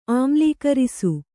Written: ಆಮ್ಲೀಕರಿಸು

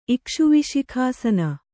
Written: ಇಕ್ಷುವಿಶಿಖಾಸನ

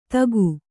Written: ಥಾಕು

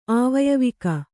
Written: ಆವಯವಿಕ

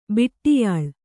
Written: ಬಿಟ್ಟಿಯಾಳ್

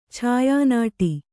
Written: ಛಾಯಾನಟ

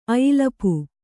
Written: ಅಯಿಲಪು

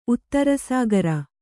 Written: ಉತ್ತರಸಾಗರ

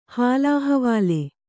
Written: ಹಾಲಹವಾಲೆ

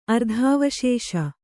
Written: ಅರ್ಧಾವಶೇಷ

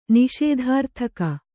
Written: ನಿಷೇಧಾರ್ಥಕ

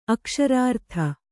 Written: ಅಕ್ಷರಾರ್ಥ

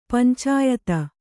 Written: ಪಂಚಾಯತ

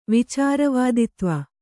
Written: ವಿಚಾರವಾದಿತ್ವ

